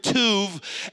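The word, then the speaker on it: tuv (טוב): If you were saying it in Hebrew, it's the word tuv.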